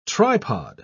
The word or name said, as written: tripod